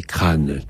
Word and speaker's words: Craon: chanson de Craon, qu'il faut prononcer crâne, comme vous savez.